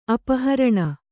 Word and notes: ಅಪಹರಣೆ: apaharaṇe

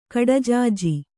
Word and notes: ಕಡಜಾಜಿ: kaḍajāji

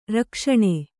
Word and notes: ರಕ್ಷಣೆ: Kannada

